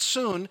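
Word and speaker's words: soon (σύν): Sin, the Greek word soon, spelled S-Y-N in English, but pronounced soon, is the idea of with or together with.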